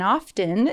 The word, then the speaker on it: often: One bit of constructive criticism the T in often is silent. I am totally guilty for this. I definitely pronounce the T.